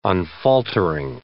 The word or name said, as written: unfaltering